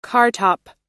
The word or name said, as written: cartop